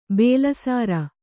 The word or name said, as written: ಬಾಲಸರ